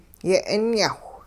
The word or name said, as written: yeh'inyahwh